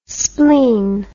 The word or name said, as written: splen